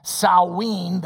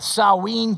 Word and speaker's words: Samhain: And it's pronounced several different ways. The one I'm to understand, Samhain, the god of the dead.